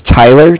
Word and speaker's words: Tyler: My name is Tyler, T-Y-L-E-R, and okay. I'm calling with Cardiff. It's spelled C-A-R-D-I-F-F, as in Frank Frank.